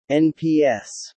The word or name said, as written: NPS